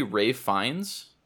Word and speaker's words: Fiennes: Like the acting in that movie, like, and I always thought his name was Ralph Fiennes because that is 100% how it's spelled. He played Voldemort for those Potterheads out there. It's actually Ralph Fiennes.